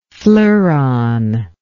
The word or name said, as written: fleuron